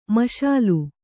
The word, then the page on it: ಮಸಲಾ: kannada